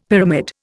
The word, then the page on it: pyramid: Indian